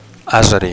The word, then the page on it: azeri: Catalan